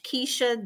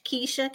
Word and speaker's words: Keisha: Um, also, you can find me at um, Coach Keisha W. Coach Keisha K E I S H A W on Instagram.